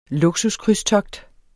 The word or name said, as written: luksuskrydstogt